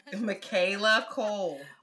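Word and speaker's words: Michaela Coel: Michaela Cole.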